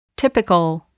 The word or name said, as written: typical